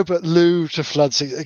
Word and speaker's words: Looe: Looe Looe is actually a place in Cornwall, folks. Don't laugh. It's spelled L O O E.